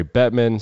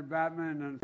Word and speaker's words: Bettman: The Tampa Bay Lightning visiting the White House, and Joe Biden mispronounces the name of the commissioner, Gary Bettman, say president. Who is that guy? – I want to thank Commissioner Gary Batman and Watch the background.